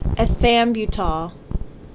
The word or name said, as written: Ethambutol